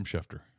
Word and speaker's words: Schefter: I pronounced his name right that time? – Adam Schefter. – Got it. – Yeah. – All right. Left out the N.